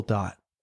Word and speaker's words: Dot: His name is Will Dot. – It's not easy to find either. – Not at all, because it's not spelled the way you think. – Mm. – It's spelled D-O-T.